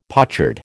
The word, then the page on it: pochard: American English